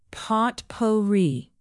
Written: potpourri

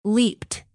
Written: leaped

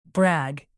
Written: brag

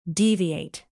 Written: deviate

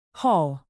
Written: haul

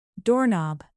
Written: doorknob

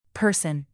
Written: person

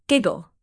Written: giggle

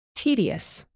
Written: tedious

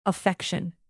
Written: affection

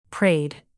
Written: prayed

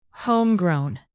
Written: homegrown

